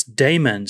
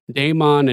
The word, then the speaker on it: Daemon: Dean says, it's Damon, spelt like mon, but sounds like man. You're killing me saying it wrong.